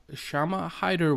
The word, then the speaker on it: Shama Hyder: And today I have Shama Hyder with me that's joining me on the podcast.